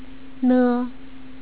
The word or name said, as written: ne